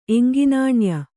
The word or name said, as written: ಎಂಗಿನಾಣ್ಯ